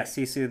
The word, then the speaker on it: Sisu: Is it Sisu? – Yeah, Sisu the dragon.